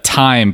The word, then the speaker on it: Thyme: Uh, that's uh, Thyme, by the way, spelled like the herb.